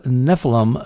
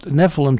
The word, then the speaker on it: Nephilim: And when I pronounced Nephilim to him, he said, no, it's Nephilim. And I thought, I am not going to dispute a man who has seen a Nephilim. I will pronounce it Nephilim, at least in his presence.